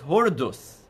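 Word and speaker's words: Hordus (הורדוס): In the Jewish pronunciation, he's known as Hordus.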